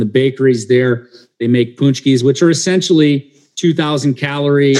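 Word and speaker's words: pączki: And I see on the slide you also have a Polish treat there that is spelled kind of weird, but is actually pronounced punchkis.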